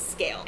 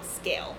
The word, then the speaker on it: Sgaeyl: And his dragon's name is Scale. S-G-A-E-Y-L. Scale. And I think that's a Gaelic word, so I could be completely mispronouncing it. But Scale, it sounds pretty legit to me.